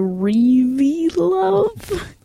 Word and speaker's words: ReVeluv: Their fan group is called This is very confusing because it's spelled R-E-V-E-L-U-V, so Reveelove. – Say that again. R-E-V-E-L-U-V. L-U-V.